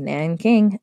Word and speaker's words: Nanking: The corpses and massacred victims, with the Japanese soldiers standing nearby Nanking, I know how to say that. It's a J, but it sounds like a K. Nanking,